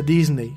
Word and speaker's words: Disney: Lo interesante de Disney Plus es que poco a poco va a ir... Eh, sí, aquí me están corrigiendo, no se dice Disney, se dice Disney.